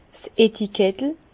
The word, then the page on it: 's Etikettel: Alsatian; Bas-Rhin; Herrlisheim